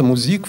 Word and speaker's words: Musik: Э, как, как произносится музик в немецком языке? Так же, как по-русски мужик, только же мужик, меняем на «з».